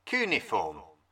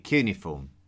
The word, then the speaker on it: cuneiform: Cuneiform. – I call it cuneiform, but you will also hear it pronounced as cuneiform.